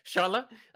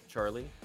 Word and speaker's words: Sharla: That is Sharla. People who do not speak my language pronounce his name as Charlie.